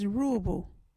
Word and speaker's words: Ruubu: our local Wadjuri word is Ruubu.